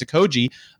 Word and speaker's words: Okogie: I thought it was Okogie, by the way, for his whole career. We got a pronunciation guide. It's Okogie, who just had a wonderful bounce-back season with the Suns, was debatably in their starting five at times.